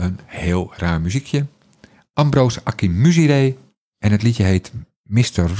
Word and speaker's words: Akinmusire: Hij heet Akinmusire. Zo spreek je het uit. En niet op een andere manier.